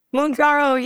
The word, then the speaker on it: Mounjaro: Is it pronounced Mounjaro? – Mounjaro, yes.